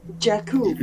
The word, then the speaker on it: Jakku: Oh, let me help you. Pronounce after me. Jakku.